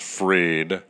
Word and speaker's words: Fried: F-R-I-E-D. I think he pronounces it Freed, but they got fried by Freed.